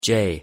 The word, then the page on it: j: English